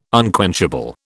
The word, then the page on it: unquenchable: British English